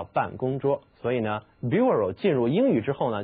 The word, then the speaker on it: bureau: 好了，顺便呢再提到一个词，它是以 B-U-R 开头的，读作 bureau， bureau 其实呢这个 B-U-R 它和 B-A-R 意思很相近，表示的是木头的棚子。这个词好像很难读， bureau 听起来不像是英语发音的这种习惯。是的，它来自法语，在法语里边呢 bureau 表示的是写字台办公桌。